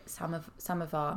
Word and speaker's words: Samovar: And you co-edit Samovar, am I saying that right? – Yeah.